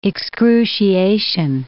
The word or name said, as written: excruciation